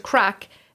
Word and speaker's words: craic: So the crack, the Gaelic word crack, is spelt C-R-A-I-C. – Ah, okay. – Crack. Oh. And over here, you think crack as in drugs.